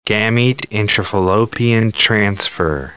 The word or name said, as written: gamete intrafallopian transfer